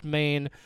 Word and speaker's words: Men: It looks like men, it's pronounced Maine.